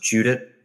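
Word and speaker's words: Judit: And also props to, I don't know if is her name pronounced Judith? Judith? – I know that there's not an H, right? It's J-U-D-I-T.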